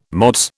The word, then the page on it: Mods: British English